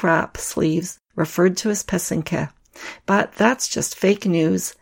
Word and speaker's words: pysanky: The word is spelled P-Y-S-A-N-K-A. The word pesenke is the plural. It's similarly spelled, but with a Y at the end instead of an A. So it's spelled P-Y-S-A-N-K-Y. The accent is on the second syllable. An easy way to remember how to say it is that the first and third syllable rhyme. So that's the pe and the ke. Put it all together and you get pesenke.